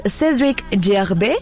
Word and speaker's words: Cédric Gervais: Ahora sí que el remix que le hizo el DJ francés Cédric Gervais, ándale, la pronunciación francesa, macho, en 2013, y esta la convirtió en un éxito masivo que ahora escuchamos.